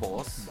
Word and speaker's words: Bose: Porque luego dicen Bose, pero es voz.